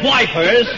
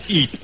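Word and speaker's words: Ypres: When we were at Wipers... Can I get you a bicarbonate? – It's not pronounced Wipers, General. It's Eep.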